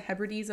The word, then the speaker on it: Hebrides: So he composed his, I think it's the Hebrides. Is that how you pronounce it? Yeah, the Hebrides Overture in Scotland because he was so inspired by the landscape.